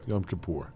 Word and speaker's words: Yom Kippur: How do you pronounce it? – Yom Kippur. – Okay. That's how I'm going to pronounce it, too.